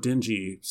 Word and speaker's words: Denji: And by him, I mean Denji, which I didn't realize the name Denji sounds like the word dingy until I listened to Bill's episode.